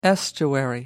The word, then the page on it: estuary: American English